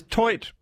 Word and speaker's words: toit: So does TOIT mean roof? – It's pronounced toi.